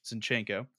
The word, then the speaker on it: Zinchenko: Okay, so Alexander Zinchenko, who I almost certainly just butchered the name. – Zinchenko, Who is a player for Arsenal, has just created a Counter-Strike team for Ukraine.